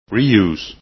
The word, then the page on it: reuse: english